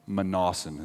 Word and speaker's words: Mnason: bringing us to the house of Mnason of Cyprus, an early disciple with whom we should lodge. He also has a hard name to say. He needs a nickname. Mnason is how you pronounce that.